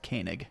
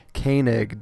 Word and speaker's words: Koenig: It's actually pronounced Koenig. – Koenig. David Koenig. I should probably get that right. The last name is spelled K-O-E-N-I-G.